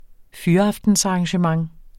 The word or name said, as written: fyraftensarrangement